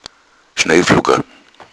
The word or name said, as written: schneeflocken